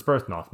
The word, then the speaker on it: Byrthnoth: It's B Y R T H. It's Brith. – Birth. Birth. Birth. Yeah, birth. It's Byrthnoth.